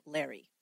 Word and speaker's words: Lauri: Now, if you've ever seen anything written about Larry, his name is spelled L-A-U-R-I. It looks like Lori, but the accounts that I found said everybody pronounced it Larry. Aileen, as you might know, is also spelled a little bit differently. It's A-I-L-E-E-N, when usually we spell it with an E.